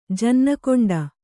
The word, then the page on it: ಜನ್ನಕೊಂಡ: janna koṇḍa